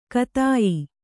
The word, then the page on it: ಕತಾಯಿ: katāyi